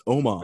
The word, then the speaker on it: Omos: He's like, that's not how you say it. It's Omos.